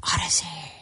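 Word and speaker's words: Audacy: Odyssey. A-U-D-A-C-Y. I know it's spelled funny, so when you go to look it up, don't start with an O. Start with an A. A-U-D-A-C-Y.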